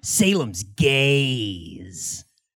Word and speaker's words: Salem's Gaze: Salem's Gaze. – Okay. – If it doesn't end with that Z, it's a very different poster. Yeah. – Oh, G-A-Z-E.